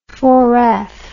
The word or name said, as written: four-eyes